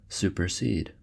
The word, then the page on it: supersede: American English